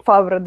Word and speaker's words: Фавро: какие фильмы делает Фавро, э, или Фавро, кто как ударение ставит. – Фавро. да, Джон Фавро, окей.